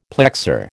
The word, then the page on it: plexor: American English